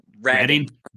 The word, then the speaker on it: Redding: Reading off-road riders? – Redding. – Redding. It's spelled like reading.